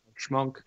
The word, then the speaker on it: Schmunk: 28-year-old Amber Schmunk. I think is how you say it. – It's like the Tony Shalhoub show, Monk. – She was charged. – Schmunk.